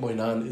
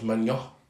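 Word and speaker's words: Maignan: I was actually talking to a lot of French people at work today, and the way to pronounce Mike Moynan is Magnon.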